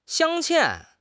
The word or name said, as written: 镶嵌